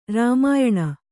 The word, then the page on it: ರಾಮಾಯಣ: Kannada